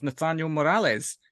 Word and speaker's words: Nathaniel Morales: And next up from within the MIH ranks, we have Nathaniel Morales, if I've pronounced that right, Nathaniel.